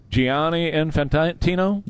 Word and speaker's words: Gianni Infantino: You guys that are soccer guys, President Trump has said that he wants the head of FIFA Gianni Infantino? – Yeah, he calls him Johnny.